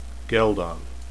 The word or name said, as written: ghealdan